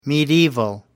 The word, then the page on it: medieval: American English